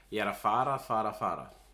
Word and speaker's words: ég er að fara: Ég er að fara, fara, fara. Or the correct grammar is ég er að fara, að fara, að fara. But you would pronounce it ég er að fara, fara, fara.